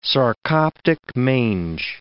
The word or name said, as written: sarcoptic mange